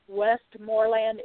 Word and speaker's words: Westmoreland: And I tell people that Westmoreland is spelled Westmoreland, like you're going west for more land.